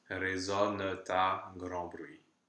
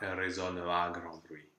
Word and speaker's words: résonne à grand bruit: So that T that was silent and résonne with just a schwa at the end becomes pronounced. Résonne ta grand bruit, not résonne à grand bruit.